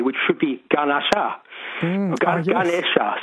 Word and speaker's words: Ganesha: Or they call the elephant god Ganesha in the south, Ganesh in the north. They delete the A's. – So the A at the end is pronounced like a schwa, Ganesha.